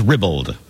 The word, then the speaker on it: ribald: Either one is correct. The first choice is Ribald.